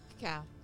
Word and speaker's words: cacao: How do I say it? Cacao? – Cacao.